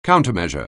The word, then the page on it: countermeasure: anglais